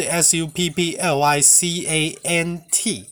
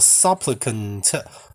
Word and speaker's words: supplicant: supplicant 是 s u p p l i c a n t。 S U P P L I C A N T，supplicant。那我刚刚讲的那个 suppliant，它的发音不一样，它是 suppliant，它也是恳求的人，所以恳求的人这个字就有两个字跟两个发音。